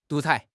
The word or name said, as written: 独裁